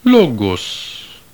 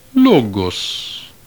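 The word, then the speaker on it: Logos (λόγος): An example of this is found in the Greek word for reason or word, which has an acute accent on the first syllable. Logos, logos. If you listen carefully, I believe you heard no greater stress or loudness on the first syllable than on the second, but you heard that the musical pitch of the first syllable is higher than the pitch of the second syllable.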